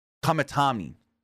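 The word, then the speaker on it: Kamitani: Number seven, I'm going to butcher this name, Saya Katam- Kamatani. Saya, Saya Kamatani.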